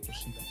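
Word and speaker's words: Rossita: Sí, rosa, Rosita, sí. Con doble S, es una S normal, pronúncialo normal y ya está.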